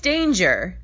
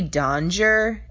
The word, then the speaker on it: Danger: Just as I get to the back, Mr. Z hollers, "Oh, Miss Danger!" He mispronounces my last name. Doesn't rhyme with stranger like everyone assumes. It's actually Donger, and I usually correct people.